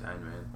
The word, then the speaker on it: Ayn Rand: He's like a big Ayn Rand fan, or Ayn Rand, however you pronounce that. – No, it's Ayn Rand.